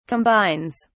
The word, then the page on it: combines: British English